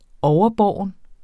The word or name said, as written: overbåren